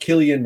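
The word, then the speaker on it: Cillian: And, so anyway, Killian Murphy, I always go with the soft C in it, but it's pronounced Killian Murphy.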